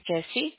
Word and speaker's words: Yesi: My name is Jessie. – Okay. Can you spell that for me? – Y-E-S-I.